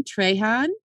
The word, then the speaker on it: Trahan: So this is from comedian Aaron Trahan, as in Trahan Solo. That's our pronunciation key.